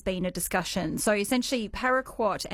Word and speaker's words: paraquat: We are going to talk, though, about a herbicide, paraquat.